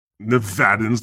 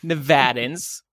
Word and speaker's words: Nevada: Nevadans. The way you said that, it was like you don't like them. – No. No. Nevadans. Yeah, Nevadans.